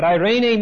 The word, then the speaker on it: eirene: But eirene means to be one in its root meaning in Koine Greek. E-I-R-E-N-E, long E, eirene.